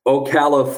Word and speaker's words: Ocala: I like to start at the beginning with all the guests, and I want to go back to and I hope I'm saying this right, Ocala, Florida. Is that right? Oh, did I say that right? – Claps for that. I am impressed. – Okay. – Yes, you said it right.